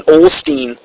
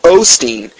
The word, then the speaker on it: Osteen: She, she left a comment on my Osteen sermon, something to the effect of um, I was pro- I was pronouncing Olstein instead of Osteen, and she says truly the Bible is you know correct as man will fail you.